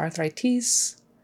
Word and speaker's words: arthritis: And make sure you get your other vowels right too. I and I. Arthritis, not arthritis. It has to be arthritis. That I. Sort of a short I sound.